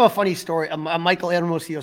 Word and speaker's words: Hermosillo: They DFA'd one of the guys that I love how you say his name. So I'm going to let you go ahead and say his name. Who did they DFA? – Michael Hermosillo. – Very good. Almost as good as Pat Hughes rolling that H.